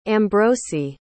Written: ambrosi

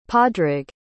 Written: padraig

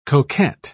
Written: coquette

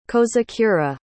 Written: kozakura

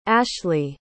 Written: ashlee

